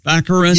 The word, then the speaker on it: Baccarin: Daredevil's girlfriend, Morena Baccarin? – Baccarin.